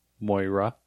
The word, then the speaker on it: Moira: Moira.